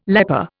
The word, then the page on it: leper: Indian